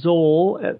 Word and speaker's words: Zol: Soul to Zol as part of his first syllable of his name. – Like Z-O-L? – Yeah, Z-O-L.